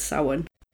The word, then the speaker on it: Samhain: And it's the festival of, it's pronounced Samhain, apparently. Mm. But spelt Samhain, and they say Samhain in the second one. – Today. – So I am apologising because I got it wrong. Apparently it's Samhain.